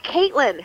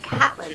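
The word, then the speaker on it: Catelyn: Like, when you hear the names on Game of Thrones, I'm like, I totally said her name like Caitlin. Her name is spelled like Caitlin. Her name is spelled Caitlin. Why are we calling her Catlin? She still gives her the nickname Cat. Even though her name is spelled Caitlin, like, that's not unreasonable, but no, her name's Catlin.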